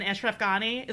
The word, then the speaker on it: Ashraf Ghani: Ashraf Ghani.